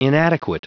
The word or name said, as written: inadequate